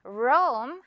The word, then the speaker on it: Rome: 로마는 영어로는 Rome 이렇게 발음이 돼요. 로메가 아니라 Rome 한 번에 발음해주면 돼요.